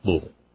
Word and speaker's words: Louisville: And that's Corey in Louisville. You got to say it like that, Louisville.